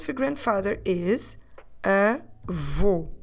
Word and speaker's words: avô: I want you to put your lips round and forward as if you're going to whistle in order to pronounce the sound u. Avô. In terms of spelling, this word takes this accent called circunflexo, or in English a circumflex, on the letter O. And that circumflex on the letter O, it's there to tell us that we need to close the vowel.